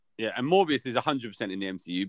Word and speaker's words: Morbius: And Mobius must, it's Morbius or Mobius? Morbius.